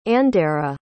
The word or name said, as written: andera